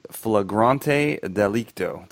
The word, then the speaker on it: flagrante delicto: The female's made her selection, and they engage in this is a great word, by the way, flagrante delicto.